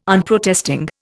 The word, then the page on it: Unprotesting: Indian